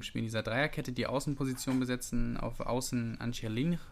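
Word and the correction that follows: Angeliño: An ja, sprachlich ist es heute unentschieden. Wir lassen es einfach mal mit den Aussprachen. – Angelino. Ähm, aber Angelino, Dankeschön.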